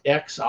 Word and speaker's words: Xi: it's XI. – I think it's Xi, like Xi, something like that.